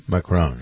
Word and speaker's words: Macron: And, well, you know, this whole business with him and Macron. What? How do you pronounce it? – Macron.